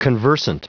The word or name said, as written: conversant